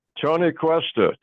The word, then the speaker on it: Cuesta: Tony Ustra, is that how you pronounce it? – Tony Cuesta. Tony Cuesta.